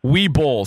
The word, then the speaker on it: Uwe Boll: Uwe Boll. So it's spelled U-W-E-B-O-L-L.